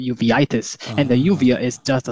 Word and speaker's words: uveitis: Uveitis? Uveitis? It's spelled U-V-E-I-T-I-S.